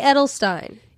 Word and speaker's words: Edelstein: I say Edelstein. – Yeah, you said it correctly. I said it incorrectly.